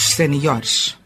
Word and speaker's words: séniores: O plural de sénior é senhores. Deve colocar-se a tónica na penúltima sílaba. É uma atividade que se destina especificamente a grupos séniores.